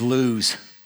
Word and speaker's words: Luz: It's interesting that you, um, the proper pronunciation is lose, is lose.